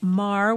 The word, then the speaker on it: Meagher: We are talking about Thomas Francis Marr, which is spelled M-E-A-G-H-E-R.